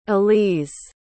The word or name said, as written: aliz